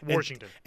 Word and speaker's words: Washington: I know there's not an R in there. – Washington.